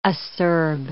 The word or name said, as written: acerb